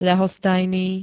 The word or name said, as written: ľahostajný